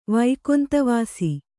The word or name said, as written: ವೈಕೊಂತವಾಸಿ